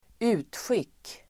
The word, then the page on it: utskick: [²'u:tsjik:]